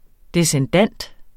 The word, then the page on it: descendant: desənˈdanˀd